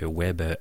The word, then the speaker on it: Ouèbe: Le web s'écrit O-U-E-B-E, donc web à la française.